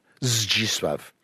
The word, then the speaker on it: Zdzisław: Zdzisław. Because the Z dead is quite possible in Polish, and then the Z with an I after it is Z and not anything else.